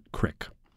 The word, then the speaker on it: Creek: He pronounces it Crick.